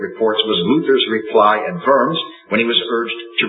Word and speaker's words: Worms: Eventually, he was brought to trial at what was called a diet, and if you've ever read about the diet of worms, cheer up, it's actually the diet of worms, pronounced with a V, not the diet of worms.